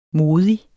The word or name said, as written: modig